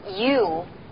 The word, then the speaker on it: U: 注意，千万不要把它发成左右的右，它的发音部位呢更靠前。听起来呢有点像汉字易物两个字呢连起来，易物 you，易物 you。